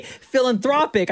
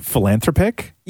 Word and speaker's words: philanthropic: Were you saying like philanthropic? – Yeah. – Yeah. Something like that. Like saying it wrong. – I kept saying like as if the person's name was Phil. I kept saying Phil.